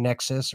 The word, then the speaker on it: Nexus: Nexus or Nixon. – Mm-hmm. – You said it right the first mods.